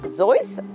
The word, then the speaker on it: Seuss: We are mispronouncing his name. It's either Zeus or Zeus.